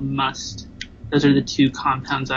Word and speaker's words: musth: So it's called must. You say it just like the word must, M-U-S-T. – Must. – Yeah, but it's spelled with an H at the end, so M-U-S-T-H.